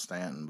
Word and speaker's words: Staunton: I think it's pronounced Stanton, but it's spelled S-T-A-U-N-T-O-N.